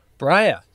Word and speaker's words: Briar: Can you write a song so we, you know, Briar rhymes with fire.